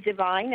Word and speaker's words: Divine: My name is Divine, and it's spelled D-I-V-I-N-E, Aubrey, A-U-B-R-Y. My website is divineblessings.one. And that's D-I-V-I-N-E, blessings, B-L-E-S-S-I-N-G-S dot O-N-E.